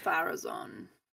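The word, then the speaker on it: Pharazôn: Farazan, you put the acento at the final syllable.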